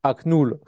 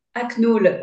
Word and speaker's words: Aknool: Aknul. – Aknul, ouais.